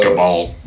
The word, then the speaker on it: Cabal: The Cable, which is Thanos. – I think it's pronounced Cabal. – Cabal, thank you.